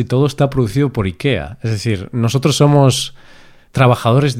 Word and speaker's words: Ikea: Ikea, Ikea, que pronuncian los anglosajones, quizá, y no sé cómo lo pronuncian los suecos, pero bueno, eso, Ikea. En España, esto es un podcast en español, así que nosotros enseñamos la pronunciación española, Ikea.